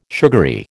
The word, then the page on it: sugary: American English